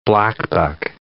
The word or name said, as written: blackbuck